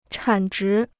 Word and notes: 产值: chǎn zhí

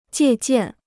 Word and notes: 借鉴: jiè jiàn

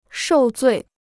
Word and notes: 受罪: shòu zuì

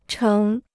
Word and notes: 成: chéng

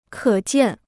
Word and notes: 可见: kě jiàn

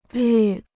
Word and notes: 率: lǜ

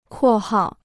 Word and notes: 括号: kuò hào

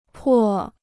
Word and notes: 破: pò

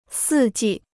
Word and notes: 四季: sì jì

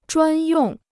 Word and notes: 专用: zhuān yòng

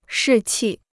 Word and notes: 士气: shì qì

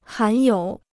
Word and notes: 含有: hán yǒu

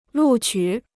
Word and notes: 录取: lù qǔ

